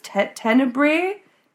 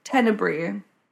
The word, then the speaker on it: Tenebrae: So he is a member of a mob called the, I don't know, I can never pronounce this, but it's Tenebrae. Tenebrae. T-E-N-E-B-R-A-E. – Tenebrae.